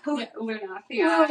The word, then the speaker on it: Lunathion: Lunathion? – Lunathion.